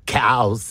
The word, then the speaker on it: Cowes: Cows. – Cows, as spelt with an E.